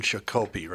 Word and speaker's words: Shakopee: And I mentioned Shakopee, right? – Shakopee. – Yeah, exactly.